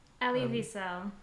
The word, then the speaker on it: Elie Wiesel: I think oh, I can't relate myself to Viktor Frankl or Eli Wiesel or Eli, is that his name? Eli Wiesel? – Elie Wiesel. – Okay, there you go, much better pronunciation.